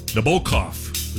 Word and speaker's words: Nabokov: The bow should be stressed. Nabokov is how you should say it.